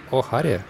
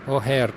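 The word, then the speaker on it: O'Hare: О'Харе? Очень хорошо. Это такой аэропорт О'Харе? О'Хэр произносится. Я думаю, что это ирландское название.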